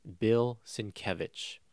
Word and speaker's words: Sienkiewicz: Bill Sienkiewicz. And Bill Sienkiewicz,